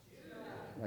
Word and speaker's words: Judah: Say it like you know it. Judah. Like Judah.